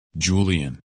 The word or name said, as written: Julian